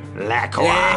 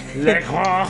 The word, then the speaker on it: LaCroix: Please pronounce it LaCroix. – LaCroix. – It's LaCroix.